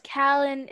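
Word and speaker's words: Kallan: All right, no, it's Callan. It's normally spelled with a C.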